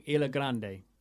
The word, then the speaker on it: Ilha Grande: No, you are saying it right. Like, so like how I said, the D's go to G. So it's Ilha Grande, but they would say like Ilha Grande.